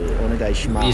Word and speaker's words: onegai shimasu: onegai shimasu.